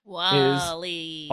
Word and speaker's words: Walley: W-A-L-L-E-Y, you guys decide. I don't know what the actual correct pronunciation there is. – Wally.